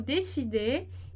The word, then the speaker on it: décidé: Avez-vous décidé? E aí, aqui a gente tem o décidé com acento. Então, perceba lá. Décidé. Décidé. Som de E, porque tem um acento ali, tá? Então, como café.